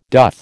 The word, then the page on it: doth: American English